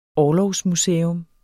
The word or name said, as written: orlogsmuseum